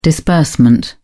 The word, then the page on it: disbursement: British English